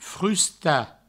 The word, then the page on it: frustar: frusta